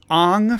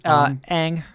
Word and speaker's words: Aang: So Laith says, Aang from Avatar. Did I say that right? – Aang. – Is it Aang? Oh, no, shoot. I said it the way that the crappy movie said it.